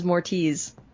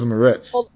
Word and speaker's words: Moretz: I'm 99% sure that her last name is Chloe Grace Mortiz or something like that. I think I've heard it pronounced. – It's Moretz. – What if I'm wrong now? Where is YouTube when we need it? – Wikipedia says Moretz.